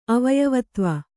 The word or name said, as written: ಅವಯವತ್ವ